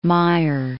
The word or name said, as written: Mayr